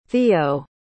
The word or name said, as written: theo